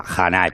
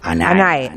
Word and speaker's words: Hanae: Lo este yo, lo pronunciaba, pues eso, a, lo español, que la H con J, Hanae, pero era Anae.